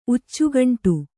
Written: ಉಚ್ಚುಗಂಟು

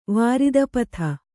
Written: ವಾರಿದಪಥ

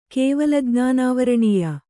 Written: ಕೇವಲಜ್ಞಾನಾವರಣೀಯ

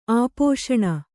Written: ಆಪೋಶಣ

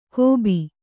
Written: ಕೋಬಿ

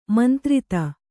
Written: ಮಂತ್ರಿತ

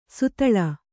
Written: ಸುತಳ

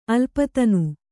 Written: ಅಲ್ಪತನು

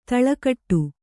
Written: ತಲೆಗೆಡು

